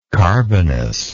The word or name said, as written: carbonous